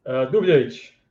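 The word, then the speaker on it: Dubljević: Dubljevic. – Dubljevic? – Yeah.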